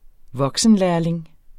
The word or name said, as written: voksenlærling